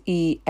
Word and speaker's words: Easwaran: And Iswaran, I think that's a better pronunciation, is Easwaran.